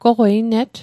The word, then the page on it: goqwei net: Mi'gmaq